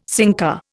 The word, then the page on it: sinker: Indian